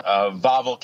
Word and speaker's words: Wawel: Wawel Castle. Yes. – Wawel, okay. – W-A-W-E-L is actually Wawel. – Okay. – The W's in Polish are apparently pronounced with a V.